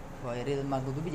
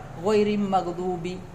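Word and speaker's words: ghairil maghdubi (غير المغضوب): Ini banyak terjadi pada orang-orang yang menggabungkan ya, menggabungkan itu ra dengan mim, ghairil maghdubi, jadi ghairim mag, ghairim mag ya, disebabkan mereka cepat dalam membaca, cepat karena terlalu cepat membacanya sehingga lamnya tidak terbaca ya, jadi mestinya ghairil maghdubi, ghairil ya, dalam di situ. Tapi karena terlalu cepat, ghairim maghdubi, ghairim maghdubi ya, itu berarti alnya dihilangkan, alnya tidak dibaca.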